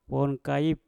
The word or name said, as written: wom kaip